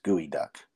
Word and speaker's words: Geoduck: And it's spelled Geoduck, but it's pronounced gooey duck.